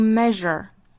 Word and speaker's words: measure: 我们再来看美音那美音的第一个词读 measure 中间仍然是有这个日的发音